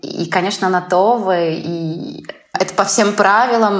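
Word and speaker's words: Tove: Просто в шведском это О, она произносится более так вот Това. То есть она между, наверное, таким средним нашим открытым О э, и, и, и нашим таким У.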